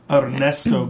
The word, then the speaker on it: Ernesto: Well, as far as Spence and Ernesto goes, that's how you have to say his name. – Okay, roll your R's.